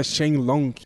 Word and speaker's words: Shenlong: Cara, eu descobri essa semana que o nome dele, que tem uma variação do nome nome dele, que, se pronuncia Shenlong.